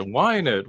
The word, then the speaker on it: wine: Yeah, yes, but you know, it is spelled, if you were like to kind of like Google it, it is spelled W-I-N-E, just like the drink, but it's actually a dance.